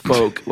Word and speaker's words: folk: In fact, up until about a year ago when someone wrote in and said, you stupid, it's pronounced folk, like F-O-K-E. – Folk. – And not folk.